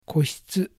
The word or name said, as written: Kohitsu